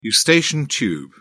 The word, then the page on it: eustachian tube: British English